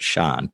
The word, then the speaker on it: Sian: She's, and I think that they probably just did that because that actress, whose name is Sian Davis, and it's spelled S-I-A-N, it's pronounced Sean, not Sean, but Sean. She's Welsh.